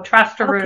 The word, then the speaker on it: Trosterud: It's Norwegian, so Trasterud okay.